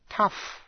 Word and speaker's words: tough: Và quý vị nghe tĩnh từ tough. Tough. T-O-U-G-H. Để ý đến cách đọc. Tough.